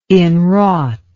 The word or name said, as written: inwrought